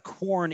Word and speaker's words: Quorn: I got some positive news out of Quorn, England. Q-U-O-R-N is how you spell that place.